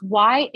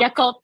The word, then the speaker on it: Yakult: Yes, it's Yakult. So it's like a yogurt type of milk, berry. – Yakult. – And the same consistency. Yeah. – Yakult. – Yakult. Then you got to say it like a Yakult.